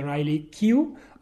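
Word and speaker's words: Riley Keough: E poi c'è Riley Q, ho controllato, si pronuncia così.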